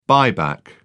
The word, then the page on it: buyback: British English